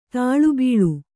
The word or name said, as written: ತಾಳೆಬೀಳು